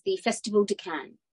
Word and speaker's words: Cannes: But you mentioned about the film doing so well in, is it Cannes? – Yeah, it's Cannes. – We were debating how you say it before, because we're not as glamorous as you, so we've never been. – I think that sounds right. You know how it's spelt with an S, but it's Cannes.